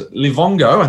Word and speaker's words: Livongo: I know in previous Shooting Stars picks, Doc had both companies Livongo, I think it's pronounced right, and Teladoc. Livongo has now been merged into Teladoc, and Livongo shares have been converted. Firstly, mate, before I keep going, am I pronouncing that correctly? Because if I'm not, mm-hmm. I'm, I'm repeat okay, good.